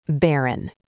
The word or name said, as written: barren